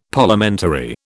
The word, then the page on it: Parliamentary: British English